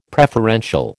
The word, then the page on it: preferential: American English